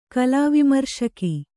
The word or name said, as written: ಕಲಾವಿಮರ್ಶಕಿ